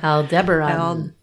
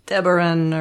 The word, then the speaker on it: Aldebaran: Aldebaran. – Aldebaran or Aldebaran. I never know where to put the syllable.